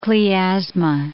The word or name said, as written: Klyaz'ma